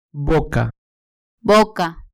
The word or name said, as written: boca